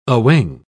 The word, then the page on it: awing: British English